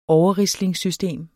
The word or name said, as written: overrislingssystem